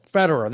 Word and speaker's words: Federer: And Roger Federer also Federer.